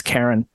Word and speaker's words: Charon: No, I used to pronounce that Charon, and then I think the Percy Jackson movies taught me that it's Karen.